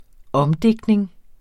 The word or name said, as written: omdigtning